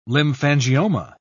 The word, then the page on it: lymphangioma: British English